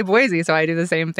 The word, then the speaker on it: Boise: Also, we call it Boise up there, just so y'all know, and that's how it should be pronounced.